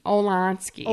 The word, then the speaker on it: Olansky: But Veronica's caught by Madame Durant and Mrs. Olenoff. Can't pronounce her fucking name. – Hold on, let me look. – It's Mrs. Olanotsky. Olansky. – Olansky, yeah.